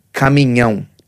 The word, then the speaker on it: caminhão: Caminhão. – Caminhão. – There you go. Caminhão. Yeah, English speakers don't want to say caminhão. You know, caminhão. It's caminhão.